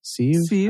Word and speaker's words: sieve: Sieve? How do you say that word? – Sieve.